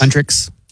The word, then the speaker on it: Huntrix: but Hunter/X. – Huntrix. – Is that how you say it? – Yeah.